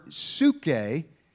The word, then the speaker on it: psuche: The word translated as soul is the Greek word psuche. And actually, if you look at it and pronounce it in the English, it looks more like psyche.